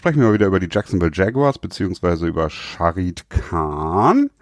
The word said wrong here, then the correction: Shad Khan: Habe ich das jetzt richtig gesagt? – Ich glaube schon. Nee, ja. Shad hm. Khan. – Shad Khan heißt er, oder?